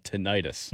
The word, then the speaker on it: tinnitus: Can confirm it's called tinnitus. – No, it's tinnitus. You need to look that up. That's actually how it's pronounced. You've been saying it wrong, as has your grandfather, for years. It's tinnitus.